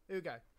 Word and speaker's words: Hugo: My grandfather doesn't pronounce my name properly because it starts with an H. And, like, in the countryside, the letter H doesn't exist. So it's like, you'll go, hey, Ugo. And you're like, you've known me for 26 years. How can you still not pronounce Hugo?